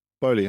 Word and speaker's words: Boehly: Is that how you say it, Boehly? – Boehly, I think. – Boehly, yeah.